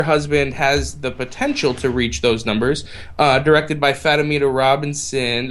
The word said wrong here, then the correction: Fatima Robinson: Did I say her name right? Fatima Robinson.